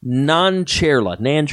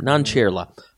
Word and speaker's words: Nancherla: Nancherla. I know everybody mispronounced her name. Nancherla. Aparna Nancherla.